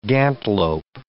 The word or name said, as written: gantelope